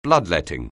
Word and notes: bloodletting: anglais

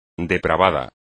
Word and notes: depravado: espagnol

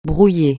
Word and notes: brouillé: francais

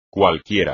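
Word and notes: cualquiera: español

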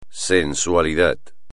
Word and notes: sensualidad: espagnol

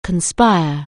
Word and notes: conspire: anglais

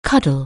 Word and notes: cuddle: anglais

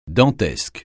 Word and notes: dantesque: francais

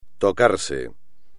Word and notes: tocarse: espagnol

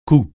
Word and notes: cou: francais

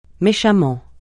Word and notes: méchamment: francais

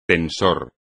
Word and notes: tensor: espagnol

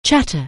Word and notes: chatter: anglais

